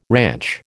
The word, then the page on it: ranch: American English